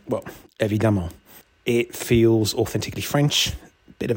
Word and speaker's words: évidemment: Bit like voila, I think the title's quite easy to say, which is unusual for a French word. Split into four syllables, évidemment.